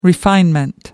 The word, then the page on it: refinement: American English